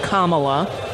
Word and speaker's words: Kamala: First of all, he knows it's Kamala, but mispronouncing her name on purpose.